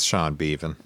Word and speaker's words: Beavan: I hope I'm saying his name right. I hope it's not B-Van. That's a weird name to say. – It's Sean Beavan.